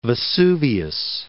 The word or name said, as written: Vesuvius